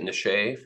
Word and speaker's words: niche: I'd appreciate it if you pronounced it niche for the rest of the pod.